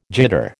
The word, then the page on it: jitter: American English